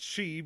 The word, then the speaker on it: Sidhe: The Sidhe, which is pronounced she because fuck Gaelic.